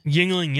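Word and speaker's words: Yuengling: Yingling, yeah.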